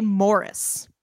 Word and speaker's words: Maurice: In England, they spell it like Maurice, but they say Morris.